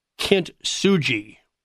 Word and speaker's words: kintsugi: Matt, in part three, you do a chapter called The Art of Spiritual Kintsugi.